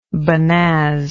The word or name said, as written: bonnaz